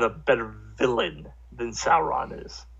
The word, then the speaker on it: Sauron: The original King Kong going against Sauron... Am I mispronouncing it? I'm sorry. – Sauron. Sauron from the Lord of the Rings trilogy.